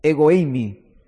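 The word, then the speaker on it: ego eimi (ἐγώ εἰμι): En el griego se pronuncia ego eimi, y es una alusión directa a la voz de Jehová que apareció ahí en la zarza con Moisés.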